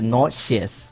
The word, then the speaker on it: nauseous: 这个单词呢，一定要注意，不要读成了 nauseous，是 nauseous。